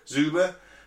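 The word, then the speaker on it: Dzyuba: The team captain is Artem Dzyuboya. Is that how you say it? – Zuba.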